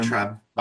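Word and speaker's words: Vayntrub: Milana Vine, I don't know, is it Vintrub? Is that how you say it, I assume? – Weintraub. – Vintrub.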